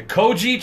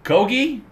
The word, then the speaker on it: Kogi: I used to pronounce it the Koji truck. – Okay. – But I was corrected by our coworker June, who says it's pronounced Kogi, not Koji.